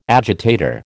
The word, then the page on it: agitator: American English